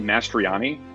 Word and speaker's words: Mastroianni: I pronounce it Mastroianni, okay. But I am well aware that the three vowels in a row is a real wild card.